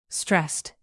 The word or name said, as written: stressed